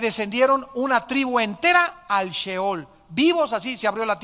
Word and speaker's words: Sheol: se escribe Sheol, S-H-E-O-L, Sheol.